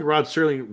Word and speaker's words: Rod Serling: Well, I feel like it's a bigger story, but Rod Serling, because I don't think Rod Serling wrote the original one, right? – You said his name right.